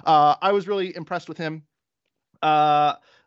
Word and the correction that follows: Zendejas: I'm going to mispronounce every single name, but Zendejas.